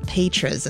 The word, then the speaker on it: Petras: I mispronounced it earlier, but Petras is, I think, how you say it, with a Z at the end there, or a Z sound.